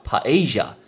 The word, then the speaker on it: paella: 那很多人看到西班牙都会想到说，西班牙有黄色的那个海鲜饭，西班牙的海鲜饭超好吃的，这个叫 paella，很特别的发音。 先要客串一下西班牙文老师。那原因是哦，我以前稍微短短学了一下西班牙文，那这些吃的单字我会记得。那西班牙海鲜饭叫 paella，paella，因为那个 l l 看到 l 就是 z 的一个发音，所以叫 paella，paella，而这就是西班牙海鲜饭。